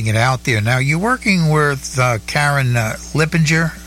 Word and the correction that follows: Leipziger: Leipziger. – I always mispronounce her name. I just can't. – That's okay. Yeah. There's a city in Germany called Leipzig. That's what the Leipziger. That's her name.